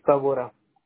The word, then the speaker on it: Tabora: Mi apellido es Tabora. – Ok, ¿cómo se escribe? – T-A-B-O-R-A.